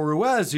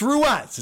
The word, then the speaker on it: Ruiz: And then he has this guy Carl Ruiz, who's his. Um, and by the way, it is pronounced Ruiz. Someone tried to correct me on Twitter. It's Ruiz. It's not Ruiz.